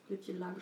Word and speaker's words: Lütje Lage: Das Lütje Lager, richtig? Habe ich es richtig ausgesprochen? – Ja, nee, ne? – nur Lage, ne? Lütje Lage. Lage. – Ja. Lütje Lage.